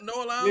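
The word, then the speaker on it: Noah Lyles: I'm saying his name, like Noah Lyle.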